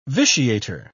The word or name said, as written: vitiator